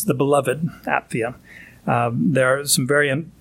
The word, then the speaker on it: Apphia: Apphia you'd pronounce the, the, the, P and the PH separate. Those are two separate letters. So Apphia, our sister.